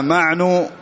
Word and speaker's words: معن: المدني ثقة متقن قال حدثنا معن بفتح الميم وسكون العين,